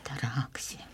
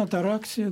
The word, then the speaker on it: атараксия: Где ударение? – Попросту безмятежность. – Атараксия. – Конечно, атараксия, да. Не атараксия, разумеется, нет.